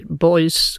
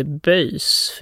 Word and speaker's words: boys: Men jag är glad, glad också att du säger boys och inte böjs, för vi har diskuterat det där uttalet, jag och lite olika besserwisser-människor på Sveriges Radio.